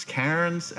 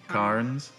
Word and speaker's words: cairns: Cairns? – Cairns or cairns. – Okay. – You can pronounce it either way, actually.